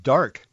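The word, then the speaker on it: Darke: She was born in, I think it's pronounced Dark. It's D-A-R-K-E.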